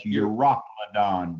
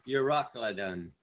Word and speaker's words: Euroclydon: But not long after, there arose against it a tempestuous wind called Euroclydon. – That's Euroclydon, brother. – Yeah, that's right. Euroclydon. Forgot the pronunciation.